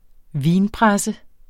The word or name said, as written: vinpresse